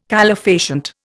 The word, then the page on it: calefacient: Indian